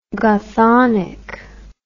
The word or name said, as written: Gothonic